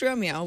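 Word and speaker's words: Roamio: If you like that, it's R-O-A-M-I-O, just so you know, although it is pronounced Romeo, which is somewhat of an unfortunate name.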